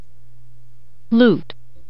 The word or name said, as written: loot